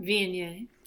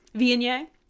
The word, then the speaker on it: Viognier: So it was a Voynier. Is that how you pronounce it? – Viognier. – Viognier.